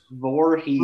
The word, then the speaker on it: Voorhees: Voorhees.